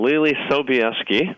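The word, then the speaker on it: Leelee Sobieski: You said it beautifully. – So Lili Sobieski. Good. – That's her Polish name.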